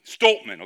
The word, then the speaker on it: Stoltman: So the World's Strongest Man, I'm probably not even saying his name right, Tom Stottleman. Stoltman, okay. Tom Stoltman is the reigning World's Strongest Man.